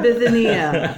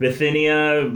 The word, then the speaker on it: Bithynia: Bithynia. That's how that word is pronounced today in this moment. – Bithynia. Bithynia.